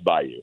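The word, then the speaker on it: Baiyu: But it's pronounced Bayou.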